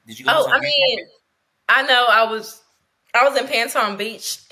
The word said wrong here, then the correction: Patong: and then did I say that right? – Yeah. No, Patong. You're right, Patong.